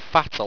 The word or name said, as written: fattle